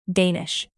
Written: danish

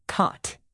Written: cot

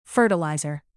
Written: fertilizer

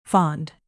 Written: fond

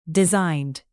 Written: designed

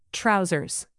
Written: trousers

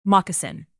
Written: moccasin

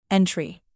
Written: entry

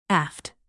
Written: aft

